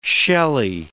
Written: shelly

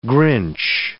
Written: Grinch

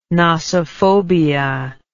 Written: nosophobia

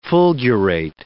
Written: fulgurate

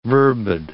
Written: verbid

